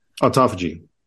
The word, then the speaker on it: autophagy: Autophagy? – Autophagy.